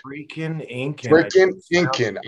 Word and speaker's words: Frickin' Incan: I want to make sure I pronounce this right. – Frickin' Incan, I-N-C-A-N. – Yeah, okay.